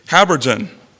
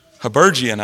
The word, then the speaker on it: habergeon: So he said Habergeon. It also can be said Habergeon, I think, is also another pronunciation of the same word, but that is correct.